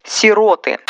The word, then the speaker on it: сироты: Сироты. Хоть слово и произошло от прилагательного сирой, смещать ударение на первый слог не стоит. Это неграмотно.